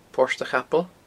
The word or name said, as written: Port a Chapaill